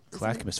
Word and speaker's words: Clackamas: Because they live in lived in Clackamas. – Clackamas, very very well pronounced.